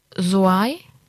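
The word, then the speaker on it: Zouaï: Soai, hast du gesagt. – Genau. Ja. Also sehr viele Vokale, seht es mir nach, wenn die Aussprache nicht richtig ist, geschrieben Z-O-U-A und dann dieses I, wo dieser Querstrich oben als I-Pünktchen drauf ist.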